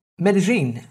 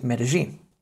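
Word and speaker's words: Medellín: In Spain, as you probably know already, it's a Y. But in Colombia, it's a J. So rather than it being Medellin, it is, or should be pronounced as Medellin.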